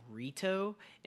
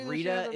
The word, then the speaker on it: Rita: They were like mispronouncing Rita's name in there, and they said Rito. And if you know anything about the Power Rangers, that's her brother's name.